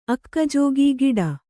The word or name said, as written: ಅಕ್ಕಜೋಗಿ ಗಿಡ